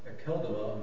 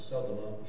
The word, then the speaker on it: Akeldama: It was known unto all the dwellers at Jerusalem, insomuch as that field is called in the proper tongue Akeldama, and can also be pronounced as Seldama, either way.